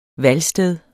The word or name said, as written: valgsted